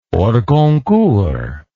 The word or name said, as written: hors concours